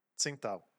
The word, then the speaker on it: Tsingtao: Eh, sobre la pronunciación china de, e, en real del nombre. Entonces haré lo que nuestros amigos españoles dijeron, como ya dije, Tsingtao.